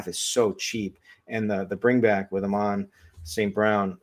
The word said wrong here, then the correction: Amon-Ra: Did I say it right? Is that? – I think you might have forgotten a syllable in there somewhere. – Yeah, there. That's what I think. Amon-Ra St. Brown.